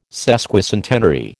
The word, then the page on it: sesquicentenary: American English